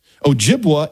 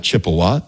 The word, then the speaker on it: Ojibwa: Ojibwa is the name of the tribe, not Chippewa. That's a mispronunciation by the French.